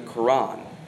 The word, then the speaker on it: Quran: Well, what was dictated from this spirit later became the Quran. Sometimes it's spelled Q-U-R-A-N, sometimes K-O-R-A-N. Became the Quran.